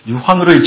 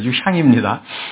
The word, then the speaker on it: 유향: 몰약과 유향과 여러분 성경 읽으실 때 자꾸 유향을 유황이라고 읽는데요. 유향은 터지는 겁니다. 유향이 프레그런스인데요. 유황으로 읽지 마시고 그거는 저 지옥불, 유황불 그때 나오는 겁니다. 여기는 그거 아닙니다. 유향입니다.